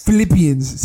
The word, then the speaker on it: Philippians: No, you see the tattoo, Philippians. You're like, what? Philippians? – Philippians. Say it just like that.